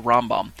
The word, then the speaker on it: Rambam: You remember and recall that 613 is the number of laws that the Rambam, the Rambam, as his name is pronounced at times as well, Rambam, he listed and organized the commandments along the lines of 613 negative and positive commandments.